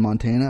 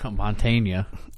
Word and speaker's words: Montana: While he was in security forces and stationed at Malmstrom Air Force Base in Montana. I probably fucked that up. – Montana. – My bad. – You said that wrong.